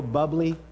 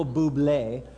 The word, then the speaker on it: Bublé: I liked it in the 90s when this new guy from Sonoma County, Michael Bubbly, That would be a Sonoma County pronunciation. Michael Bublé, when he sang, it's beginning to look a lot like Christmas.